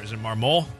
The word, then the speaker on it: Marmol: Angel Hernandez, he threw out Lance Lynn and Oliver Marmol.